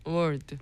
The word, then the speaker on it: word: word. – 네, 뭐 단어들 words라고 하죠. 네. 그리고 최악 worst. – worst. – 이 끝 부분이 중요하죠. th, z, and st.